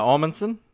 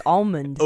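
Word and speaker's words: Omundson: And Tim Amundsen, am I saying his name right? – Yeah, Amundsen. – Amundsen. – Amundsen. – Amundsen. – Like Amundsen. – I keep thinking it's Almond.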